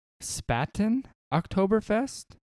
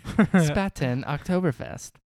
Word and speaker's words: Spaten Oktoberfest: And for me, I brought, I hope I'm saying this right, Spaten Oktoberfest. – Sounded so natural. – Did it? – Yeah. – Spaten Oktoberfest.